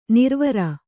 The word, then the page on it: ನಿರ್ವರ: nirvara